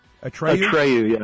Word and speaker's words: Atreyu: Atreyu, yeah.